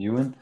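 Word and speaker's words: Ewen: Is that Owen? Ewan? – How would you pronounce that? – Ewan.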